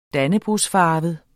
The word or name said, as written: dannebrogsfarvet